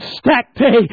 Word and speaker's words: stacte: First of all, here we find this ingredient called stacte. I'm not sure if I'm pronouncing that to your liking, but that's how I pronounce it.